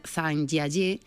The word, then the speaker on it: Zhangjiajie: sería Zhangjiajie, con Z e con H o principio.